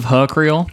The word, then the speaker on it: Hukriel: Am I saying it right? – I was going with Hukriel, because it's kind of like my name, you know? – Okay.